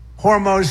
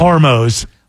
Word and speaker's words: Hormuz: Pretty soon I'll be declaring the Hormuz Strait a territory of the United States. – It's kind of a laugh. – He's laughing at his mispronunciation. – Hormuz.